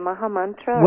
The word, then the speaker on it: Maha Mantra: Now, the song we listened to before we came to air was My Heart Bows Down to You, which has the, do you pronounce it the Maha Mantra? Is that how you pronounce that? – Yeah, you can say the Maha Mantra.